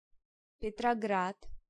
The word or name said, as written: Petrograd